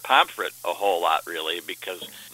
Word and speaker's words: Pomfret: I was saying more like Pomfret, but I noticed you said Pomfret. – Yeah, I think most people pronounce it almost like an I, but I think it's just it's got probably a little bit quicker way that when people pronounce it, it comes out as Pomfret. I think either way is okay, but I think most people in the area, it comes out sounding a little more like an I probably.